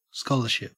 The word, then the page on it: scholarship: Australian English